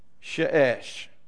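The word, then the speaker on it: she'esh (שש): The word for six in Hebrew is she'esh. All right? In Hebrew, we'd spell it with a shin and another shin.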